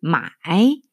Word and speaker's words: 买: 어, 근데 이제 재밌는 게 이게 마이 하면 삼성으로 읽으면 어, 사다예요. 저희가 뭐 물건을 샀다 이렇게 할 때 사다. 근데 물론 한자는 다르지만 발음은 같아요. my, my, 그러니까 my, my. 그 대신 성조가 다르죠. my 사성으로 읽으면 팔다예요. 상인이 되는 거죠. 내가 고객으로 살 때는 my, 그 다음에 내가 물건을 파는 상인이 될 때는 my 가 되는 거예요.